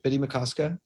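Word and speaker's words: Bde Maka Ska: Like, is it Biddy Makoska? Is that how you say it? – Yes, I believe so.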